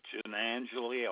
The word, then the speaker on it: Giannangeli: My last name is Giannangeli. I'll spell it Giannangeli.